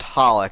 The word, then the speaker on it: HOLiC: Yeah, yeah, it's just, it's pronounced Holic. The three X's are silent.